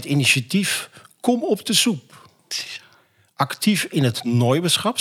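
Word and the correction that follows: Noaberschap: zeg ik het goed? – Nee? Nober. – Noberschap.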